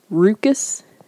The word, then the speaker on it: ruckus: Ruckus juice, except it's pronounced rucus. Rucus juice.